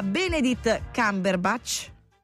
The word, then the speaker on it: Benedict Cumberbatch: Allora, un plauso a Benedict Cumberbatch,